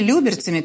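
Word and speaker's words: Люберцы: И если беспилотники снова полетят с юго-запада, они могут пролетать над Люберцами, Балашихой или Люберцами. Простите меня, пожалуйста, за это ударение. Люберцами, конечно, Балашихой или восточными районами Москвы.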